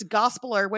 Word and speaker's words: Gospeller: So all the all the chapters rhyme with Gospeller. So Hope Yeller, Swamp Dweller.